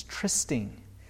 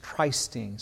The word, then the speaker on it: trysting: I just mentioned to you, we looked this up a little bit, that the American pronunciation is trysting. But the British is trysting. So since we're in Canada, I think trysting sounds nicer.